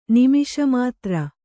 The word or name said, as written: ನಿಮಿಷಮಾತ್ರ